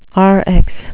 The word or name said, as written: rx